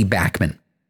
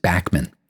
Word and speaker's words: Bachman: Uh, the leader of BTO is named Randy Bachman except that nobody pronounces it Backman. Everybody looks at how Randy's last name is spelled B A C H M A N, and they see the B A C H part, and they think of Bach, the composer, and suddenly he's become Randy Bachman.